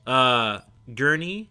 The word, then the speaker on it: Gurney: Gurney, which is Josh Brolin's character.